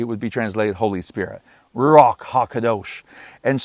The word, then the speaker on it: Ruach: It's spelled R-U-A-C-H. It's a hard C-H. It's almost like a cough K. Ruach.